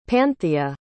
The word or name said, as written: panthea